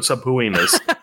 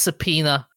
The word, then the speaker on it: subpoena: you've got subpoenas. – By the way, it's pronounced subpoena. – Yeah. Yeah, did I say that earlier correctly? – Nope, you said subpoena.